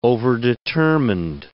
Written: overdetermined

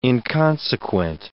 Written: inconsequent

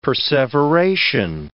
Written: perseveration

